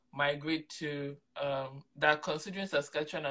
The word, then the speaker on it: Saskatchewan: Well, the first thing I'm going to tell everyone who's looking at this province and interested here is that the correct pronunciation is Saskatchewan.